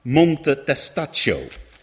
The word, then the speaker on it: Monte Testaccio: If you've ever been to Rome, you may have seen the Monte Testaccio. That's roughly how you pronounce it, I think.